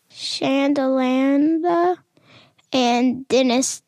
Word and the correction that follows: Sharelanda: You're really close. It was Sharelanda.